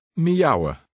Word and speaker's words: miauwen: The I and the AU combination in the second word are pronounced separately. – Katten miauwen.